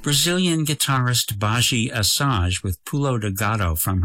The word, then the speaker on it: Badi Assad: Her name is spelt B-A-D-I-A-S-S-A-D, Baji Asaj.